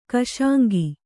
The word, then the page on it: ಕುಸಂಗ: kusaŋga